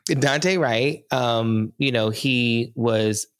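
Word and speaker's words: Daunte: And what I'm talking about is I hope I pronounce this right. I think it's Dante, right? – Yeah, it's Dante.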